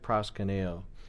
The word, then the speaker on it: proskuneo: I could spell it out for you in English letters: P R O S K U N E O. That's not how it's written in Greek, but then you guys could look at that, pronounce it proskuneo, and then I would say, which is translated worship or bow down.